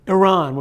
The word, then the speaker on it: Iran: So I want to start with Iran or Iran or however the, how do you pronounce it? Iran? – Iran would be just as well.